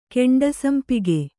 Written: ಕೆಂಡಸಂಪಗೆ

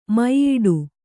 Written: ಮೆಯ್ಯಿಡು

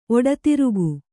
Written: ಒಡತಿರುಗು

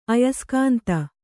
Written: ಅಯಸ್ಕಾಂತ

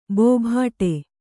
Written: ಬೋಬಾಟ

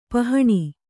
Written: ಪಹಣಿ

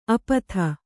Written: ಅಪಥ

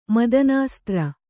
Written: ಮದನಾಸ್ತ್ರ

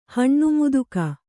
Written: ಹಣ್ಣುಮುದುಕಿ

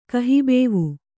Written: ಕಹಿಬೇವು

